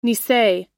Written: nisei

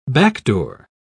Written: backdoor

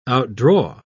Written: outdraw

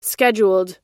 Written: scheduled

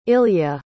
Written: ilya

